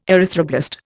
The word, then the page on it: erythroblast: Indian